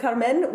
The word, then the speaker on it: Carmen: With Carmen, we stick with her name pronounced basically in the Spanish way, basically pronounced with the way we expect it. But Don José, then we pronounce that the French way.